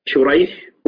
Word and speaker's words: شريح: فشريح بن النعمان وسريج بن النعمان، الأول بالشين المعجمة والحاء المهملة وهو تابعي يروي عن علي، والثاني بالسين المهملة والجيم وهو من شيوخ البخاري، فهو النوع الذي يقال له المتشابه.